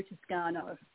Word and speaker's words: Tuscano: Yeah, I just go by Joe Tuscano.